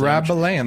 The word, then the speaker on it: Rabelais: Rabelais, that's correct. The S is silent.